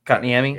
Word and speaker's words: Kotkaniemi: And the how do you say his name? I can always fuck it up. Kakinemi? – Kakinemi, yeah. Kotkaniemi, yeah. Something like that.